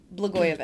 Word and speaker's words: Blagojevich: Blagojevich? Blagojevich.